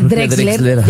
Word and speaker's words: Drexler: Drexler, nunca sé decir bien, Jorge Drexler, ya lo dije bien.